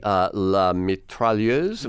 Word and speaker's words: La Mitrailleuse: Certainly La Mitrailleuse, am I saying that right? – Yes, about the first